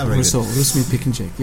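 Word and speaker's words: Russo Piknjač: Russo Pickinjack, yeah.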